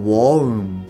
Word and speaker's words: won't: えー、 won't、まあ、実際にはアポストロフィー t が発音されないので、えー、 won't と wanna もう一回言います。